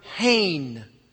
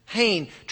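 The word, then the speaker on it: hain (חן): And by far, the most common word in the Hebrew text of Scripture is the Hebrew word hain. Hain, transliterated into our English as H-E-N, pronounced hain, which could, by the way, variously be translated as grace, graciousness, favor, kindness, even beauty, adornment.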